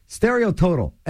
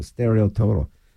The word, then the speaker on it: Stereo Total: Well, it's spelled T-O-T-O-L, like total, so Stereo Total. Yeah, but they're from Europe, so it's Stereo Total. And put an E on the end of it.